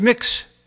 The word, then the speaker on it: Mx: I read of a teacher at an elementary school in Florida who bullied students and adults into using MX as a pronoun. It's pronounced mix.